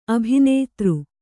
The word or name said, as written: ಅಭಿನೇತ್ರಿ